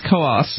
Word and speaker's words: Coos: Coos County, and I trust Brad has been pronouncing it correctly, but I trust everyone else has been as well, because there are a lot of people who move here call it Coos County. It's not Coos County. It's Coos. – It's spelled C-O-O-S, but there's an umlaut or something in there.